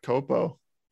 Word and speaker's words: COPO: Is it pronounced COPO? I've always called it Copo. – It is Copo.